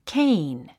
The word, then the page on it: cane: kéin; ケイン